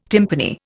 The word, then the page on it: timpani: Indian